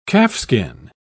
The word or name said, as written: calfskin